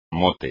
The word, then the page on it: mote: espagnol